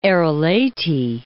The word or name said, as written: Arelate